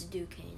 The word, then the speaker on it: Duquesne: It's Duquesne, I think.